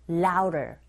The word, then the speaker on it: louder: 우, 어라는 모음 사이에 드 사운드가 꼈어요. 역시 트 사운드처럼 이 강몸과 양몸 사이에 드 사운드 역시 R 소리에 가깝게 들리게 되는 현상이죠. So louder, 틀린 발음이 아니라 원어민의 입에서 나오는 소리 자체가 louder 이렇게 들리거든요.